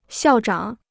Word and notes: 校长: xiào zhǎng

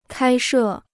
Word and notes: 开设: kāi shè

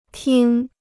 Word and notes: 听: tīng

